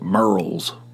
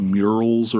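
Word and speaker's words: Murrells: That's right, Merle's. Tony tries to pronounce it like the way it's spelled, murals or something. I said, that's not the way the locals pronounce it. It's Merle's.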